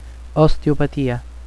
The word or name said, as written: osteopatia